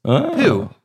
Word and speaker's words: Pou: It's poo, but it's pronounced P-O-U. Sorry, it's spelled P-O-U.